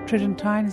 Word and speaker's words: Tridentine: Tridentine, is that how you pronounce it? The Tridentine Mass? – Sure.